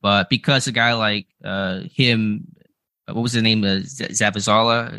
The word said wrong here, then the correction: Zavala: Am I saying that incorrectly? Probably Zavala. Yeah.